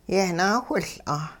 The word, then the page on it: yehnaawhiL'a: hupa